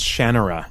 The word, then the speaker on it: Shannara: Oh, yeah, and I should mention I mispronounced, I think it's pronounced Shannara.